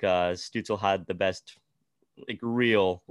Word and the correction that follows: Stützle: Well, you know, you might have to pronounce his name right if you want him to win the rookie of the year, because it's Tim Stützle.